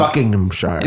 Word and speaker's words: Buckinghamshire: No, you got to drop a syllable there, I think. – Buckinghamshire. – There you go.